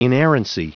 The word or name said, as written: inerrancy